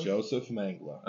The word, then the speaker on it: Joseph Mengele: Joseph Mengele. – I don't know why you didn't say Angel of Death. – Okay, so you said Joseph Mengele? – Joseph Mengele.